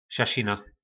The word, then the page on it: sasina: po nasimu